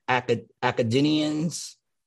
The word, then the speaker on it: Akkadian: Akkadians. – Akkadian is how you pronounce that.